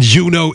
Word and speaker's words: Juno: And Juno is spelled just like it. Just like it looks. J-U-N-O. Pronounced Juno.